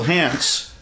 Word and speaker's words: Hance: Oh, how do you say Gil's surname? – Hance. – Yeah, I do too.